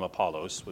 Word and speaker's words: Apollos: I hear people pronounce it different sorts of ways. I double-checked the actual Greek, and if you want to call him what his mama would have called him, Apollos would be his name.